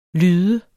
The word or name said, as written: lyde